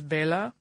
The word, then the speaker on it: Bela: Oh, and I wanted to mention briefly before I go to that, I know we've gotten some flack over our pronunciation of Bela Lugosi's name. I will tell you why. It's because when I pronounce it correctly in the Hungarian language, which is Bela, I sound like the Count from Sesame Street.